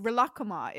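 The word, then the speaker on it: Rilakkuma: It's pronounced Relakuma because it's Japanese.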